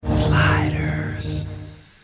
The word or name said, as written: Sliders